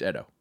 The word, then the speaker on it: Edo: It's Edo. That's how you pronounce that.